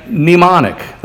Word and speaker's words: mnemonic: memorial and mnemonic, that has a silent M on the beginning for those who are keeping score at home.